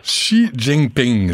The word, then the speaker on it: Xi Jinping: Xi Jinping, je vais apprendre à le prononcer comme il faut. – Oh, bon. Tu le prononces bien? – Oui, merci.